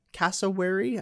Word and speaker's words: cassowary: Cassowary. I think I'm pronouncing that right. Yep.